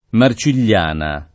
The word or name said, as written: Marcigliana